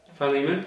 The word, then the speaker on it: Philemon: Philemon, okay.